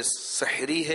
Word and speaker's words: سحر: عربی کے مشہور لغت القاموس میں ہے کہ سحر اس کھانے کو کہتے ہیں جو صبح کے وقت کھایا جائے. سحر ہا پر زبر, حوالہ فیضان رمضان صفحہ نمبر 1003.